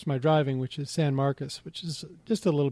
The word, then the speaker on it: San Marcos: I think I can extend my Kyle stories into San Marcos. You would think it's M-A-R-C-U-S, but it's C-O-S, but locals just say it like a guy named Sam Marcos, but it's spelled San Marcos.